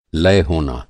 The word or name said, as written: लय